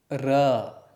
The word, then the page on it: r: sanskrit